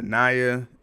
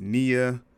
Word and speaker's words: Nia: Her name is not Naya, it's Nia.